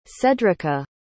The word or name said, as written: cedrica